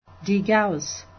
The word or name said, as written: degauss